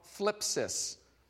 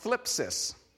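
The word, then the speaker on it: thlipsis: The tension, the word is called phlipsis. And I am saying that properly, even though it sounds like I'm saying it with a lisp. It's called phlipsis.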